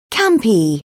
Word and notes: campy: British English

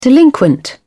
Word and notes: delinquent: British English

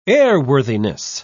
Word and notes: airworthiness: British English

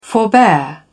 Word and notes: forbear: British English

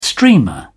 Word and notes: streamer: British English

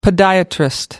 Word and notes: podiatrist: American English

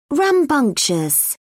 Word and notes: rambunctious: British English

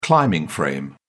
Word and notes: climbing frame: British English

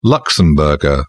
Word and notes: luxembourger: British English

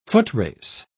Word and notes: footrace: British English

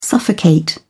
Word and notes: suffocate: British English

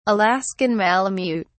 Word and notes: alaskan malamute: British English

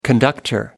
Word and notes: conductor: American English

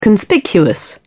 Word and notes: conspicuous: British English

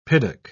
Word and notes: piddock: British English

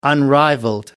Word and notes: unrivaled: British English

